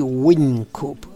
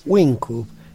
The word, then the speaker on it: Whincup: Las reglas de Gregory Wynkoop, que se escribe W-H-I-N-C-U-P. Wynkoop,